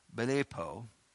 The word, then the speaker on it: blepo: It's B-L-E-P-O. B-L-E-P-O. Belepo. Belepo. That's the best way I can pronounce it. 991. If you want to study it some more. Belepo. B-L-E-P-O, it means to discern, but to look well.